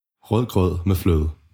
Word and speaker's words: Rødgrød med fløde: Rødgrød med fløde.